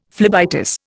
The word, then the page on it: phlebitis: Indian